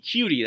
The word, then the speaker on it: Coutee: Coutee, I'm sorry, Keke Coutee, that's how you pronounce it. I uh, apologize for that, but it's Coutee.